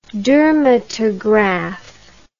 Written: dermatograph

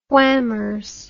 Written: whammers

